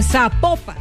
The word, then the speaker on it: Zapopan: Oiga, nos escriben, nos escriben los oyentes y que no se, no se pronuncia Zapopan, sino que se pronuncia Zapopan.